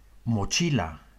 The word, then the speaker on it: mochila: De klemtoon valt in het Spaans meestal op de één na laatste lettergreep. Dit is altijd het geval wanneer een woord eindigt op een klinker, zoals mochila.